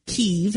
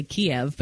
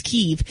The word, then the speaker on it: Kyiv: Mainstream media has been like nuts over this, and there's been news reporters who have gone into in Kyiv, and that's the correct pronunciation. We tend to say Kiev, but it's Kyiv.